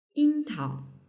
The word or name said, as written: yingtao